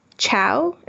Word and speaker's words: Chao: Chao, and my last name is spelled C-H-A-O.